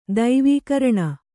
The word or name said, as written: ದೈವೀಕರಣ